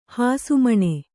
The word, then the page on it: ಹಸುಮೀನು: kannada